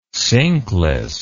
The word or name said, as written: sinkless